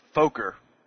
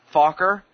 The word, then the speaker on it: Fokker: And the way they pronounce Fokker, they rhyme it with poker, so it's Fokker, not Fokker.